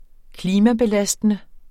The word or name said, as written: klimabelastende